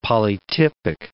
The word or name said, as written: polytypic